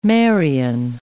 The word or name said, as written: Marian